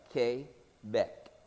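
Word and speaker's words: Québec: We serve the Lord in the province of Quebec, and that's how they say it, just like Quebec.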